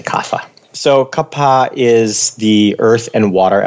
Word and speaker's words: Kapha: The third dosha is kapha or kapha. – Kapha. – If you really want to be correct about your Sanskrit pronunciation.